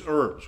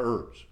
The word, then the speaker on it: herbs: Another, who is weak, eats herbs. What are herbs? It's not herbs, by the way. The H is silent.